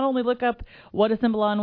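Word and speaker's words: cimbalom: Is that how you say it? Because I don't know. Cymbalon? – That was quite good, yeah.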